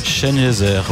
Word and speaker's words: Sengezer: Et alors que sur le banc, on a le numéro 24, Mohamed Sengezer. Ça se prononce comme Ebenezer, Chenezer, ouais.